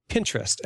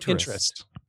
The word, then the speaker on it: Pinterest: And it's apparently pronounced Pinterest, as in Pinterest. – Yeah. Pinterest, like I am a Pinteresting person.